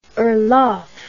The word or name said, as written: orlov